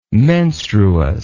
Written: menstruous

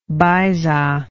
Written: baisa

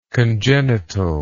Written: congenitalness

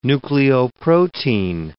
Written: nucleoprotein

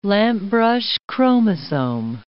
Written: lampbrush chromosome

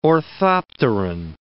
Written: orthopteran

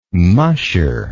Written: musher

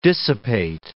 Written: dissipate

